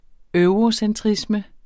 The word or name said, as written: eurocentrisme